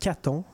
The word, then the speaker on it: Cathon: On, parle d'un roman graphique qui est fait par l'artiste Caton, que j'adore énormément. Ça s'écrit C-A-T-H-O-N pour ceux qui veulent chercher.